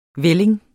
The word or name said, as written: vælling